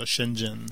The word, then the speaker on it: Shenzhen: Euh, en passant, Alex, Internet fait dire que Shenzhen, ça se prononce comme ça, Shenzhen.